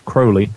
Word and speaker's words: Crowley: And I'm certainly not a practicing Crowleyite, if you want to call it that, although his name is actually pronounced Crowley.